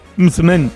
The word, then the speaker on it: msemen: Mucemen, yo que sé, en otros lugares se conoce como regaliz. Es una palabra complicada, decía, es M-S-E-M-E-N. Mucemen, mucemen, ¿vale? Y se pronuncia como lo ha hecho Hakima.